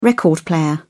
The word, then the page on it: record player: British English